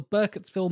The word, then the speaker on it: Burkittsville: They travel to Burkittsville, Maryland. That is the correct way to pronounce it, not Maryland, as many fellow Brits have pronounced it over the years.